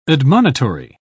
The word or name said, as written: admonitory